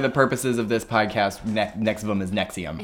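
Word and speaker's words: NXIVM: I pronounce it Nexium, like the heartburn medication.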